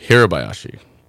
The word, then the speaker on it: Hirabayashi: Very close. Hirobayashi. – Hirobayashi. So yes. It depends on the syllables and how we say it.